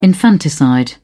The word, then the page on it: infanticide: British English